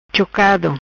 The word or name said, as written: Chocado